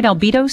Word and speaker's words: Albedo: Albedo Spray Vessel? – That's right.